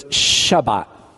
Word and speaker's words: Shabbat: And in the Hebrew, a lot of times they'll pronounce it with a sh. So it's Shabbat or the Sabbath.